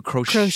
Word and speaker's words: crocheted: baby, and you knitted a whole, or no, you crocheted. Crocheted. Crocheted. Crocheted. Oh. yeah, crocheted. Well, there's a T in there. – Yes. It's yes, a silent T.